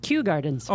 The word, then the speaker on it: Kew Gardens: From New Gardens, New York? – Kew Gardens. – Oh, Kew Gardens.